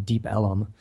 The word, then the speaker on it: Deep Ellum: There's not an official, to the best of my knowledge, there wasn't an official area, but there's an Elm Street, and they just kind of elongated the pronunciation and it became Deep Ellum.